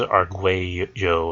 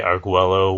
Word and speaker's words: Arguello: and he told me how to pronounce his name. And I'm pretty sure it was Arguello or something like that. He did say Arguello was probably good for those of us who weren't, like, privy to his native Brazilian or Portuguese pronunciation,